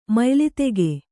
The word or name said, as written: ಮಯ್ಲಿತೆಗೆ